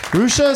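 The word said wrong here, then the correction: Hrishi: is that right? Rusha. – No. – How do you say it? – Rishi. – Rishi.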